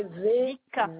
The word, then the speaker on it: Vika: Vika. V-I-K-A. – Oui, voilà, comme ça se prononce.